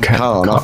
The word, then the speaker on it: Caen: How do you say C-A-E-N in France? Is it Caen? It's not K-N, is it? Is it Caen? They're hardcore. Ooh, from Caen. – I would say Caen, yeah.